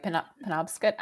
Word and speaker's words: Penobscot: Penobscot. I think that's right. Anyway. – I think you're right, Penobscot.